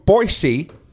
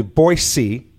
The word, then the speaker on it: Boise: Joining us now from Boise, and you'll notice, Sam, I pronounced it correctly, Boise, not Boise.